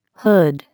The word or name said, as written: hood